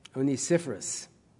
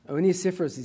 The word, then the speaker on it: Onesiphorus: Onesiphorus. Onesiphorus is his name.